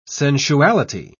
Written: sensuality

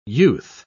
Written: youth

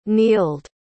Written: neeld

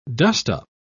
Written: dustup

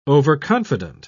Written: overconfident

